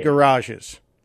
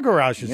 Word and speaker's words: garage: So, yeah, there were garages there, yeah. Except in Chicago, Johnny. Johnny, we don't pronounce it garage. We pronounce it garage. It's a garage. – Garage. But garage. – It's like a one-syllable word.